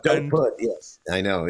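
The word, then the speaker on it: Dopud: It's pronounced Serbian, so it's Dopuj. – Dopuj is the right way.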